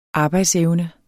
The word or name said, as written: arbejdsevne